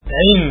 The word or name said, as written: ain